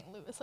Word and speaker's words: Louis: It's pronounced Louis. – Like the city is? – L-O-U-I-S. Well, this says the S is silent, but I think it's just an American just, pronunciation or an English pronunciation.